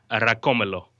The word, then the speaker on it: Racomelo: You pronounce this how? – Racomelo. – Racomelo. – Yeah, nice.